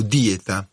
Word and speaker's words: dieta: La parola dieta, noi usiamo la parola dieta, in greco dieta deriva dal greco, veniva denominata con l'accento spostato, dieta.